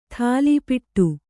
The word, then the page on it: ಥಾಲೀಪಿಟ್ಟು: thālīpiṭṭu; Kannada